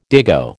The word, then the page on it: dago: American English